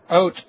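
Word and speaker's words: haute: It's Positively Prehistoric, How Foraging Has Become the Latest Obsession in H-A-U-T-E, I'm not sure how to say that, cuisine. – Oat, the H is silent.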